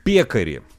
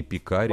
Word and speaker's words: пекари: Пекари. Или пекаревые. Кто это такие? Не пекари, важно. – А пекари, правильно говорить.